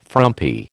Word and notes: frumpy: American English

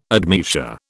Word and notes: admeasure: British English

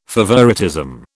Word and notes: favouritism: British English

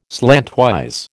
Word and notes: slantwise: American English

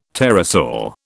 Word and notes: pterosaur: British English

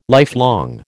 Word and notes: lifelong: American English

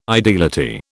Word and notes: ideality: British English

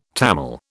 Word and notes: Tamil: British English

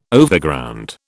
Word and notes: Overground: British English

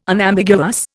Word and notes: Unambiguous: Indian

